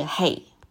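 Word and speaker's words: hey (ה): 所以在 5785 年头尾都各有一个五，五作为希伯来字母的第五个字母，在希伯来文的发音是 hey，它的意思就是恩典，它也意味着气息或说风。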